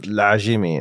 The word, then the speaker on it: Ladjimi: Oh, my full name is Skander Lajimi. – Lajimi. And what is the story behind your name? – So Lajimi actually is pronounced Lajimi in Arabic.